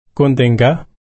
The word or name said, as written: kuntingga